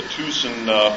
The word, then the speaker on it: Thyssen: That's actually the correct pronunciation of T-H-Y-S-S-E-N, which is a German, a Dutch name. The Dutch, I'm told, pronounce Y's like U's, and the Germans pronounce double S like a Z, so it's actually pronounced Thyssen.